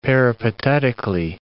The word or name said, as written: peripatetically